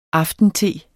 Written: aftente

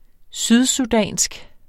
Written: sydsudansk

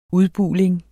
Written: UDBULING

